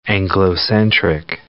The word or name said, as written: Anglocentric